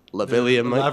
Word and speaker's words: lavalier: And one of my guests, Milan, shout out Milan, he came around and he had these small lavalier, I think that's how you pronounce it, lavalier mic.